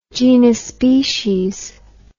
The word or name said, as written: genospecies